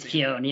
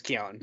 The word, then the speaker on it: Keoghan: I believe it's Keoghan, yeah. – Yeah, it is Keoghan.